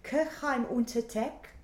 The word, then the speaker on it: Kirchheim unter Teck: Hans-Jörg, you're a lighting designer, and you're originally from, correct my pronunciation here, Kirchheim unter Teck. – Nearly perfect, yeah.